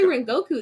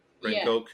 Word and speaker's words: Rengoku: He says it like Neguska, but it looks like it's spelled Rengoku. But he says they Neguska. Say Rengoku though on the show. – Rengoku, yeah. But it sounds like the way Tanjiro says it is Neguska.